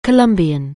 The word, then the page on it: Colombian: anglais